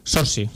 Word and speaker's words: Saoirse: Con la Saoirse Ronan esta. – Saoirse, sí.